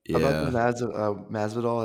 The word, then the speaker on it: Masvidal: Masvidal, that's how you say his name.